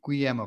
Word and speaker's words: Guillermo: Well, I know double L is Y. but I'm still not sure how you pronounce it. And The Shape of Water was reportedly Guillermo, probably.